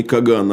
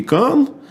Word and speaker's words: кан: Э, ну, на самом деле, правильнее произносить даже не Каган, а великий кан, э, Но, так сказать, в русской транскрипции утвердилось Каган.